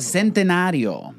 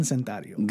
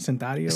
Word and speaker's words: Centenario: Centenario. – Gran Centenario. – Gran Centenario. You're missing a syllable. – Oh, am I? Centenario. – Centenario. – What was I saying? Centario? – Centario.